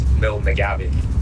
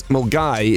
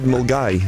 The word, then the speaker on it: Milngavie: There is one north of Glasgow called Mulgay, but it's actually called Mill McGabby. But everyone in Mulgay gets annoyed if you call it Mill McGabby. – What? – Yeah, so it's Mulgay is the place, but it's spelled Milngavy.